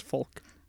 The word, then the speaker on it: folk: Folk, palm? – Folk. – Chulk. Chulk. – Oh, come on. I would not be a folk singer if I had to pronounce folk. – Oh, I think it's way better. – Oh, no. Yeah. It's folk.